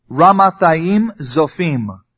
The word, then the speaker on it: Ramathaim Zophim: Now there was a certain man of Ramathaim Zophim, is the Hebrew pronunciation,